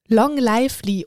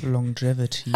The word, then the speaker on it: longevity: Long Lively oder Long Livity oder wie auch immer dieses Wort ausgesprochen wird, also Langlebigkeit. – Longevity. – Ha, guck mal, gut, dass wir dich dabei haben. Also das Ziel nicht nur viele Jahre. – Longevity. – Longevity, Longevity. Also das Ziel nicht nur viele Jahre zu leben, sondern vor allem viele Jahre gesund zu leben.